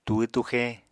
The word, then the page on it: túhw-túxe: apatani